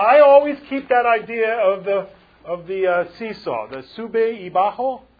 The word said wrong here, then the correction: sube y baja: How do you say it? Sube y baja.